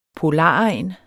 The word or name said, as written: polaregn